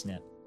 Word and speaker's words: SNP: The kind of DNA profile that they used was called a single nucleotide polymorphism, or SNP, pronounced SNP.